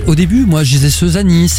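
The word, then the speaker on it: Suzane: Dans un instant, Christophe Maé et Seuzani. Je l'ai bien prononcé ou pas, Ombline? – Très bien. – Très bien, oui.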